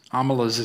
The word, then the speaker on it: Omelas: Amelas is a place name. It's spelled O-M-E-L-A-S.